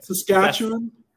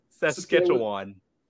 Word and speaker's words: Saskatchewan: I mean, is he the third string for the Saskatoon, the CFL? – Oh my God, that pronunciation, my guy. – Saskatchewan. – Saskatchewan. – Yeah. Okay. Good.